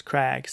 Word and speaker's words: craig: That's how the Welsh word for rock is spelt and pronounced, crag, Craig.